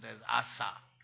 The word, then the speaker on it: Asa: Not the computer, okay? This is, therefore, I pronounce it as Asa.